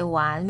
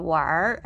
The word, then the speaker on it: 玩: 玩 you can say wan without er or war as Beijinger would pronounce it, war with er in the end.